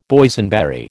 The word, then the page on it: Boysenberry: American English